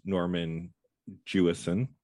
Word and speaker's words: Norman Jewison: I'll say in terms of shots themselves in this film, the director was Norman Jewison.